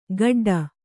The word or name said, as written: ಗಡ್ಡ